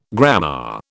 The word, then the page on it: grammar: British English